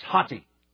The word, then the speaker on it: hoti: That's hati. H-O-T-I, little, nice little Greek word, sometimes pronounced, I guess, and not a long O, it's kind of haughty.